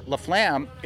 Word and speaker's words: LaFlamme: LaFlamme, it's spelled like LaFlame with two M's and an E, but do you know about the drumming technique called a flam? – I don't.